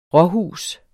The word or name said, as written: råhus